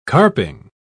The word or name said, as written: carping